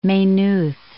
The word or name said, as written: Maynooth